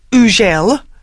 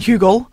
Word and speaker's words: Hugel: His name is actually pronounced Ugel, French. I've been saying Hugel, not that. Ugel.